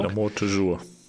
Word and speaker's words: L'amour toujours: wo Leute zu dem Lied L'amour toujours. Ich bin kein französisch sprechender Mensch, darum entschuldigt meine Betonung. – L'amour toujours. – L'amour toujours, danke.